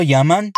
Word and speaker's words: llaman: E na sequência, para confirmar se é tudo mega diferente mesmo, ela pergunta o seguinte: Todos lo llaman de maneira distinta assim? Percebeu como pronunciei o verbo llamar com aquele i, porque quem está falando é a Lisette, que é mexicana.